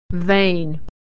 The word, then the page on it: vein: e-ey